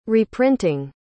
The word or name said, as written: reprinting